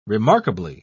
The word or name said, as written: remarkably